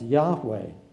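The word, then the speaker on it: Yahweh (יהוה): Those four capital letters, the word Lord is capitalized that way because underneath that word in Hebrew, there is a word which is probably to be pronounced Yahweh. It's a Y, H-W-H.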